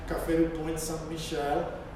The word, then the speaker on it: Café du Pont Saint-Michel: takže se správně francouzský vyslovím Café du Pont Saint-Michel, tedy lidé oddaní Maratovi se scházeli v této kavárně.